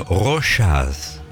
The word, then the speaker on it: Rochas: Pronuncie. Mire, primero vamos a ver la R. – Ay, perdón. – Rocha. – Es que no conocía la marca, perdón. – Bueno, Rochas. Rochas.